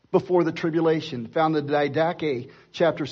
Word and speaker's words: Didache: but it's called the Didache.